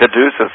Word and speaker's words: Caduceus: as did Moses' first Cataeuchus. How did you pronounce Caduceus. – It? Caduceus, that's correct.